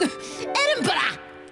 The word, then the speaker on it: Edinburgh: Edinburgh!